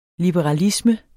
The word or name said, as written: liberalisme